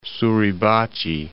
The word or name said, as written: Suribachi, Mount